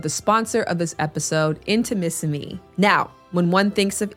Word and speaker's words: Intimissimi: But the name can be a little challenging to pronounce. Mississippi. It is Intimissimi, the art of Italian lingerie.